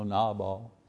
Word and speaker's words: Nabal: I mean, Nabal, and that's the way you pronounce his name, you know, Nabal.